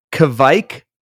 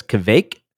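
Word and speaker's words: kveik: K-V-I-E-K, I believe. – Never would have guessed that. – No. Sometimes, I think, depending on the dialect, it can be pronounced as Kvake.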